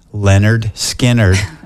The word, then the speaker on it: Lynyrd Skynyrd: Lynyrd Skynyrd, they made an album and it's pronounced Lynyrd Skynyrd.